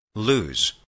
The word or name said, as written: lose